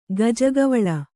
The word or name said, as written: ಗಜಗವಳ